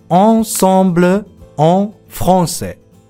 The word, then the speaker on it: ensemble en français: ensemble en français ほら、